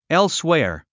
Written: elsewhere

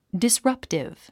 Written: disruptive

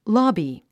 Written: lobby

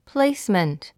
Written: placement

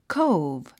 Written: cove

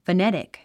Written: phonetic

